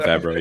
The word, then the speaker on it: Favreau: But I think overall the storyline, I'm a big fan of Favreau, or if I pronounce that right, Favreau. – John Favreau, yeah. – Favreau, So I'm a big fan of his writing and what he does.